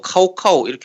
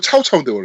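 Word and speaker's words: 曹操: 야, 근데 우리 나이 때 삼국지 2로 배우면은 조조라고 하는 거 카오카오 이렇게 하게 되잖아. – 그렇지. 카오카오, 류베이, 음. 관 관유, 음. 장페이. 그렇죠. 네, 중국식 발음이잖아요. – 네. – 네. 카오카오가 아니고 차오차오인데 원래.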